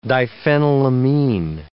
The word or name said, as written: diphenylamine